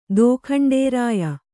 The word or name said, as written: ದೋಖಂಡೇರಾಯ